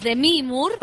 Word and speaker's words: Demi Moore: También está Cynthia Erivo, está Demi Moore, porque lo estamos diciendo mal y a ella no le gusta que lo pronunciemos así de mal. Es Demi Moore.